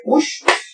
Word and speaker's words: Puig: Y estaba un chavo que se llama Enrique Push. Su apellido se escribe Puig, pero pues se dice Push.